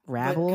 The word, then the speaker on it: Ravel: Listen, no shade to Mr. I don't even know how to say his name, Ravel. – Yeah, Ravel.